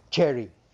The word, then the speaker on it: Chirey: Chirei, en México sí lo conocemos así como Chirei, pero aquí en, China es eh, Cherry.